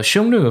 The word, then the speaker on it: Xiongnu: Now, Xiongnu, of course, is the modern Mandarin pronunciation of the name of this people. Most scholars now, I think, agree that the name probably sounded something like Hunnu in Old Chinese, in early Middle Chinese.